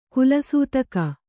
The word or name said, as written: ಕುಲಸೂತಕ